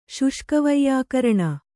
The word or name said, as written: ಶುಷ್ಕವೈಯಾಕರಣ